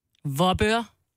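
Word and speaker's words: Vorupør: V-O-R-U-P-Ø-R. – Vorbør. – Ja, det er rigtigt.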